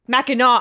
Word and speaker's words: Mackinac: It gets confusing because Fort Mackinac is pronounced with the knack, but for whatever reason, the island is naw. So there you go. Sorry for saying that wrong. Mackinaw Island. There we go. So we're going to say like a macaw. That's how I will remember it. Like the parrot.